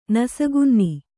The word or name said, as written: ನಸುಕುನ್ನಿ